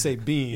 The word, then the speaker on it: Sabian: Sabian spell S A B I A N. But pronounced Sabine, yeah.